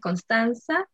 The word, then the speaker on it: Constansa: Ya, bueno, como dijeron, Connie, mi nombre es Constanza, eh, se escribe C-O-N-S-T-A-N-S-A.